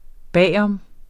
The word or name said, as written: bag om